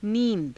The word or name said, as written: nii~da